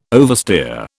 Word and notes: oversteer: British English

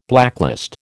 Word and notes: blacklist: American English

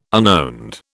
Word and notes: unowned: British English

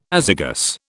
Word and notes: azygous: British English

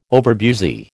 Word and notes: overbusy: American English

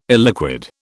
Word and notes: illiquid: British English

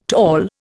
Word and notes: tall: Indian

